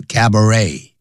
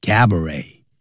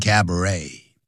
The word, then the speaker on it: cabaret: 什么哪两种东西结合在一起？Dance hall 也就是舞厅，以及 and cabaret。以及有表演的餐厅啊，有节目表演的餐厅结合在一起，你可以跳舞，又可以用餐，同时也可以看到舞台上的表演，叫 cabaret, 那也是法语，所以那个 T 是不发音的 cabaret, 也有人念成 cabaret.